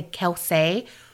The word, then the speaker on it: Kelsay: And also, just an aside, their name is spelled K-E-L-S-A-Y, so I wanted to say Kelsay, but on Unsolved Mysteries, they pronounced it Kelsay, and you know, you can take whatever Robert Stack tells you to the bank. – Wait, how is it spelled again? – K-E-L-S-A-Y.